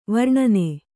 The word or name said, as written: ವರ್ಣನೆ